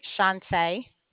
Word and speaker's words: Shawnta: Shantae. – Shantae? – Shantae. – How do you spell that? – S-H-A-W-N-T-A. – W-N-T-A? – Mm-hmm.